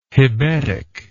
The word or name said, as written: hebetic